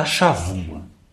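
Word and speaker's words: Chavua (שבוע): Bom, nós já comentamos que tudo gira em torno da chavua. Escreve chabua com B, mas pronuncia com V, como no espanhol, né? Chavua.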